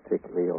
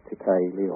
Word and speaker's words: Tikele: I call her Tikali, or Tikali, or Tikili. It's spelled T-I-K-E-L-E.